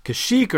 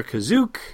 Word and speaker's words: Kashyyyk: Mustafar, Kashyyyk or Kazook, depending on whether you're an OT or a PT pronunciator.